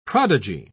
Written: prodigy

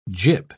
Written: gyp

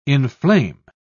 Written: inflame

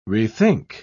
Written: rethink

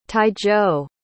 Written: taizhou

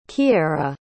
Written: Keara

